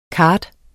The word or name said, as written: kart